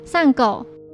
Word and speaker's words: 参悟: 而横沟的名字之后就被作者青山刚昌取为参悟，日文也是念作散狗。